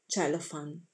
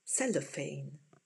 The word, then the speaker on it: Cellophane: anticipato dal singolo Cellophane. O meglio, per gli anglofoni, cellophane.